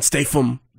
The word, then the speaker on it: Statham: Because Jason Statham. – Is the greatest action star of all time. – Jason Statham. Not bad. – No, guys, you F. – Statham. – Yeah. – Statham. – Jason Statham.